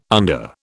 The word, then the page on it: under: British English